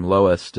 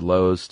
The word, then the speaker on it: loess: And you had these big areas of dusty wind, and there's actually a few ways to pronounce this, L-O-E-S-S. – Mm-hmm. – Heard everything from Lois to Lowe's to Less.